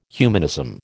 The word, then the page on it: humanism: American English